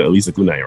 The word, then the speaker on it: Elissa Cunane: I said Elissa Cunane, right? – Yeah, you did.